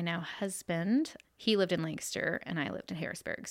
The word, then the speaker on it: Lancaster: And you said it right. I didn't mean to cut you off, but you said Lancaster. – Yes. – Okay, because people normally from out of the area. – Lancaster.